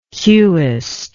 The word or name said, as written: cueist